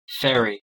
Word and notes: ferry: American English

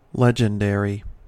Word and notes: legendary: American English

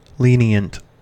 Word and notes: lenient: American English

